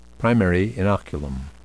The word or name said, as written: primary inoculum